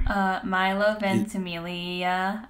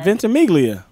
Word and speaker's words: Ventimiglia: Milo Ventimiglia. I think the G silent. – Ventimiglia.